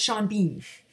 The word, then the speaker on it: Sean Bean: The fact that Sean Bean should be pronounced either Sean Bean or Sean Bon, but no, it is pronounced Sean Bean.